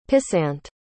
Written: pissant

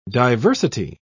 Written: diversity

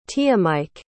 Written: tiyamike

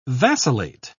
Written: vacillate

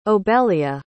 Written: obelia

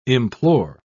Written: implore

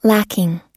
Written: lacking

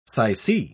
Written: sycee

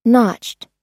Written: notched

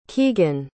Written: Keegan

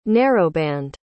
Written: narrowband

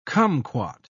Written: kumquat

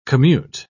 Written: commute

